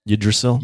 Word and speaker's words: Yggdrasil: Yggdrasil.